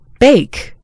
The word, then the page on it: bake: American English